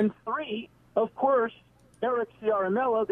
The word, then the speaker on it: Ciaramella: and it's Eric Ciaramella.